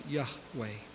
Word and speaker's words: Yahweh: A name indicated by the Hebrew consonants that would be roughly equivalent to YHWH, generally thought to have been pronounced Yahweh.